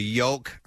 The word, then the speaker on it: yolk: So you have the yolk. – Brain has the shell on it. – I pronounce the L in yolk, yes.